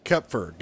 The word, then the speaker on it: Kopfer: It depends if you want to say it in German or English, but yeah, Kopfer, like a silent O, okay. Is how most people pronounce it. – Kopfer, got it.